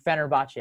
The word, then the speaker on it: Fenerbahce: Finally, contract terminated from Arsenal to Fenerbahce. Did I say it right, Josh? – Yes, you did.